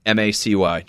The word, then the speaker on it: Macy: Macy, just like the store.